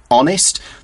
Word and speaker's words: honest: It's spelled H-O-N-E-S-T. Like many words that start with H, it's a silent H. So it sounds like honest, but it's spelt with an H.